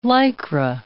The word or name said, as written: Lycra